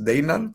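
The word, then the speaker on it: Deignan: por delante de Dañan, como dijo Laura. Es Deinan, pero ya dijo Dayan.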